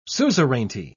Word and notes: suzerainty: British English

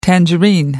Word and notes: tangerine: American English

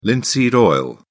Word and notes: linseed oil: British English

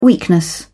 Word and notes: weakness: British English

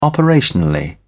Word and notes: operationally: British English

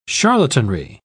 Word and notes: charlatanry: British English

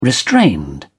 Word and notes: restrained: British English

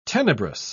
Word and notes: tenebrous: British English

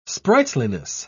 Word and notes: sprightliness: British English

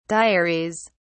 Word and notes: diaries: British English

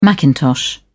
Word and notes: macintosh: British English

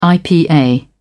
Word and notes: ipa: British English

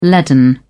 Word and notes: leaden: British English

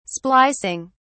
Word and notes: splicing: British English

